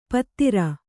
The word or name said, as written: ಪತ್ತಿರ